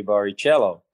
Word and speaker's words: Barrichello: It um, it depends where you are in the world. Because in England, if you want to say Barrichello, it's tough. So they say Barrichello. And uh, in in Portuguese, Brazilian Portuguese, it's Barrichello with the two R's pronounced he.